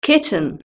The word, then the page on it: kitten: British English